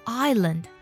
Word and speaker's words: island: 还有下面的岛屿，岛屿这个单词呢拼作 island，这里呢我们把它读作 island，而不是 Iceland，是 island。